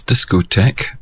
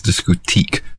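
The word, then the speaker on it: Discothèque: It's a song called Darling of Discotheque by Pizzicato 5. In actual fact, they horribly mispronounce it as Discotheque.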